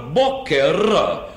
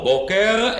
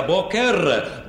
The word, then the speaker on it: בוקר: לכן יש לומר בוקר, בוקר פטישית, ריש פטישית, בניגוד לריש גרונית. לא בוקר, אלא בוקר, בוקר, כן?